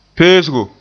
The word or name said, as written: peesgo